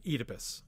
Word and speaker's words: Oedipus: In England, they pronounce the O-E vowel in Greek as a long E. Oedipus is how most English people will say that name.